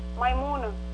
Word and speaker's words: Maimouna: Maimuna. – How do you spell that? – M-A-I-M-O-U-N-A.